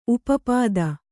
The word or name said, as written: ಉಪಪದ